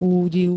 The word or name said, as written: údiv